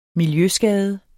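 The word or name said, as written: MILJØSKADE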